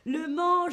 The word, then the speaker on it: Le Mans: C'est une ville qui s'appelle le plus loin Mans avec un S à la fin qu'on ne prononce pas évidemment. Le Mans, je vous ai mis une carte, c'est une ville qui se situe dans les pays de la Loire.